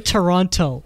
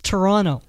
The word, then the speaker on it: Toronto: Don't say Toronto. It's Toronto. There's no T. – It's like that. That's how it is with Atlanta. It's like if you pronounce the second T, you're a cop.